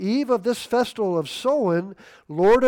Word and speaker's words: Samhain: Well, that's not the proper pronunciation. Irish Gaelic is, it means for summer's end, and the pronunciation is so in with the O-W like a cow, or Samhain. It's not Samhain. It's Samhain.